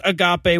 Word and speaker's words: agape: And we also got a message from all but six of you correcting my pronunciation of agape on last week's quiz show segment. So the Greek word I was referring to is pronounced agape, which means the sloppy agape thing is a little bit closer to a rhyme. And I should have known that because I said it was a Greek word and I know there's no silent E in Greek.